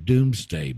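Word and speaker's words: Domesday: It's spelled D-O-M-E-S, Dome, but it's pronounced Doom, Doomsday Book.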